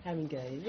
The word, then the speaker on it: Haringey: Haringey.